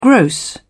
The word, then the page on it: gross: British English